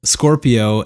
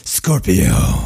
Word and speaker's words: Scorpio: No, I don't think that's how it's pronounced. – Oh, pardon me. Scorpio. – Oh, that's it.